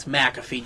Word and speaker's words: McAfee: I think McAfee, and that's how you say his name, it's McAfee, John.